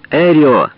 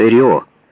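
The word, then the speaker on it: Эррио: Пожал руку, смеялся, повторял «Эрио» на русский лад. Эрио постарался произнести свою фамилию с ударением на первом слоге.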